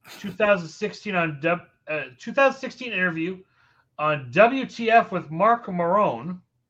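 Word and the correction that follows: Maron: a show that was launched September of 2009. – Marin. – Thank you for that pronunciation correction.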